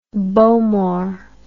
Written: Bowmore

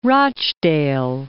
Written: Rochdale

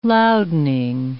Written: loudening

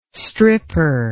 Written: stripper

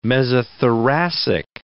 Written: mesothoracic

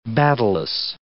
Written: battailous

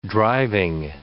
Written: driving

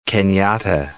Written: Kenyatta

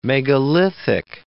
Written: megalithic

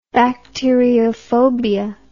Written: bacteriophobia

